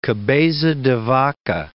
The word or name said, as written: Cabeza de Vaca